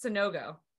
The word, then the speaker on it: Sanogo: Is it Adamu Sango? – I think it's Sanogo.